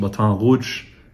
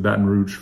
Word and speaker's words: Baton Rouge: For those of you that speak French, Baton Rouge, literally the red stick, but everyone pronounces it Baton Rouge from Louisiana, here in the United States of America.